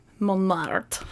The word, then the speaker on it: Montmartre: It would be Montmartre. – Montmartre. – Because it's M-A-R-T-R-E, right?